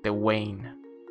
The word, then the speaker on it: Tewain: Es como Swain, pero con T en lugar de S. Tewain.